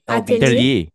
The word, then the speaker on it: atelier: Atelier's? LBT? A-T-E-L-I-E-R-S. – Atelier. Atelier. It's like a workshop. It means workshop.